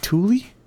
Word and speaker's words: Thule: Did you see the, so I think it's, is it Thule? – That is how you pronounce it.